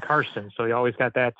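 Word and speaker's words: Czarson: Well, it's spelled with a Z, though. So it's C-Z-A-R-S-O-N. But okay.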